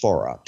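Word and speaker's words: Fårö: Now, this is an island, the spelling looks in English like Pharaoh, but it's pronounced Farah.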